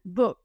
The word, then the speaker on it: book: Y que suene la K final, book. No digan book y que no suene la K. La K tiene que sonar fuerte, book, book.